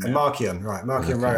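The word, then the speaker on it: Marchion Ro: There's a solid five that are kind of like, But I've only read the first High Republic novel, but Martian Rowe, I don't know if that's how you pronounce his name. – How you pronounce his name. It's Markion. – Markion Rowe.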